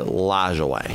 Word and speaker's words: Lajoie: His name was Napoleon Lajoie, and his last name is spelled Lajoie, and you pronounce it Lajoie.